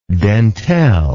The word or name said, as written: dentelle